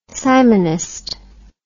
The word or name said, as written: simonist